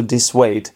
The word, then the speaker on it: dissuade: I think it should be pronounced to dissuade. So I wasn't putting the stress on the right syllable.